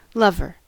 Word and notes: lover: American English

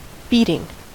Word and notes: beating: American English

